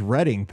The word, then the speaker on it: Reading: And we appreciate he said it's pronounced Reading, Pennsylvania, apparently.